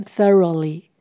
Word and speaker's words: thoroughly: Beat them thoroughly. Beat 敲击、拍打。这边的 them 是指他们，也就是指鸡蛋，把蛋黄跟蛋白打在一起。 Thoroughly 彻底的、完全的。副词修饰前面的 beat。 th 舌头要伸出来，gh 不发音。 Thoroughly, thoroughly。